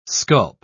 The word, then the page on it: sculp: British English